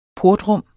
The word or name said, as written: portrum